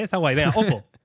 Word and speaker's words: Oppo: Oppo.